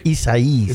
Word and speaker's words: Isaias: Yes, and that's Hurricane Isaias. – Oh, excuse me. – Isaias.